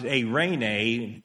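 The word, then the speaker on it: eirene (εἰρήνη): And in the Greek text, it's pronounced eirene.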